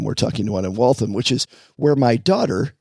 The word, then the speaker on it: Waltham: Waltham? Is it Waltham? – Waltham.